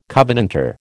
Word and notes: covenanter: American English

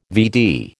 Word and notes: VD: American English

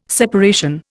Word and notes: separation: Indian

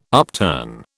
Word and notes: upturn: British English

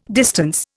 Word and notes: distance: Indian

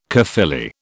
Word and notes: Caerphilly: British English

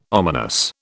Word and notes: ominous: British English

